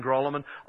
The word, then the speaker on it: Grolimund: I don't even bother saying Grolemond. I just spell it for them. G-R-O-L-I-M-U-N-D.